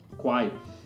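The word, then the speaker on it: Khwai: E em algum lugar falava desse, dessa reserva chamada Kwai, que se escreve K-W-A-I, não é isso? – Não. – Não, errei. K K-H-W-A-I. – Quai.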